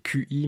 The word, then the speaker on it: Qi: ça s'écrit QI, mais apparemment il faut dire Qi.